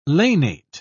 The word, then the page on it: lanate: British English